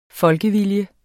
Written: folkevilje

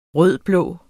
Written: rød-blå

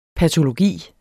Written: patologi

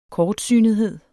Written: kortsynethed

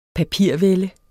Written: papirvælde

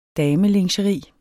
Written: damelingeri

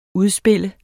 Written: udspil

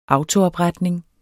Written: autoopretning

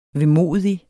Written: vemodig

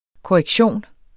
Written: korrektion

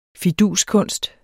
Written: fiduskunst

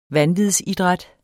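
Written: VANVIDSIDRÆT

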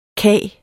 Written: kage

